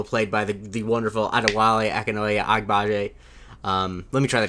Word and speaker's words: Adewale Akinnuoye-Agbaje: Adewale Akinoye Agbaje.